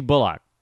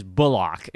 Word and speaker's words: Bullock: I have been not kind enough to Reggie Bullock, and I'm not over-pronouncing his name. His last name actually is Bullock. It's not Bullock. Got to give it the full Ock treatment.